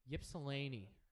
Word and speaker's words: Ypsilanti: In Michigan, he's better known as Ysplante. Actually, hold on. – No, how do you pronounce it? Ysplante? – Ypsilanti.